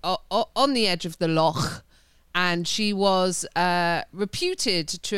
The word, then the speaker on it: Loch: I have to say it like that because Paul will listen to this and I just go lock on the lock and he's like, is it spelt L-O-C-K? No, it's Loch.